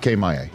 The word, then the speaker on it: Maye: Drake May is getting 30% of first team reps. – No, he's not. Drake Maye. – Get it right. – I was like, did I mispronounce his name? – No.